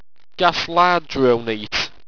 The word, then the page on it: gastlardrounete: Owstwecian